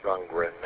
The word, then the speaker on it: Rundgren: Rundgren. I always say that wrong. I always put a T on it for some reason.